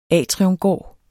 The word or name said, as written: atriumgård